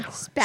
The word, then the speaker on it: Spavinaw: We are live here in Spackawa, Oklahoma, hanging out at the... – Where? Say that again. – Spavinaw. – Spell that. Oh, Spavinaw. I said Spavinaw.